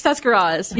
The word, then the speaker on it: Tuscarawas: Tuscarawa. – Oh. – That's where, that is not. – It's how do you say it? – That's, I can't. It's Tuscarawas.